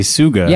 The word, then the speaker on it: Tsuga: This is just a suga. – Yeah, this is a straight suga. – Straight sugan up in here. – Spelled with a T, everyone.